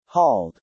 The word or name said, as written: hauled